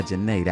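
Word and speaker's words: Zhané: It's spelled Z-H-A-N-E, but the name of the album is called Pronounce Janae. Yeah. So by Janae, that was La La La.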